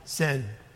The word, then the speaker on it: syn: In the English, it's spelled S-Y-N, sin.